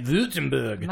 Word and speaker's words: Württemberg: But Carmella, in Württemberg, Germany, I think that's how you say it, Württemberg.